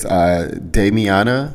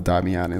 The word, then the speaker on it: Damiana: But the tea I'm sipping on, or will be sipping on in a minute, today is Damiana. I think it's pronounced Damiana. I think it's Damiana. It's Damiana tea. It sounds like Tatiana.